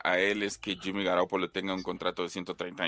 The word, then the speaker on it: Garoppolo: Hay alrededor de cuatro a seis equipos de la liga que pueden estar interesados en los servicios de, Jimmy Garoppolo, Garapolo, porque él así lo pronuncia.